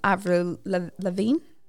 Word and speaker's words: Avril Lavigne: There's one that's similar to Avril Lavigne.